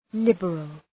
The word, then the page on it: liberal: ‘lıbərəl